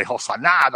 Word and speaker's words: Hosanna: And when you put the accent real hard on the last syllable and you say Hosanna, that means come and save us now.